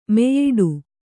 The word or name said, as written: ಮೆಯ್ಯಿಡು